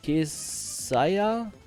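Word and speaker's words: Kaseya: Aber diese Kesaya, oder wie spricht man die aus? Kesaya? Geschichte habt ihr ja alle mitbekommen, oder? Mit diesem Softwareverteilungstool. Also, es gibt einen Anbieter. Guck mal hier. Wie schreibt man die? Oder Kesaya? Spricht man die so? Ja. Ja. Genau.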